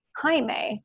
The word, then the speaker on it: Jaime: And remember that my name is spelled like Jaime. So it's J A I M E.